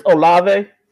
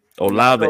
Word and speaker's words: Olave: It's Chris Olave. Olave.